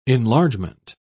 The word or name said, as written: enlargement